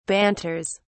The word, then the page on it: banters: British English